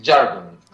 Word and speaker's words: Jargon: Yes, I'm Jargon, and the other one is George, the bass player, Pagidas. – George. So Hello. You say Jargon with like a hard J? – Yes, I pronounce it Jargon.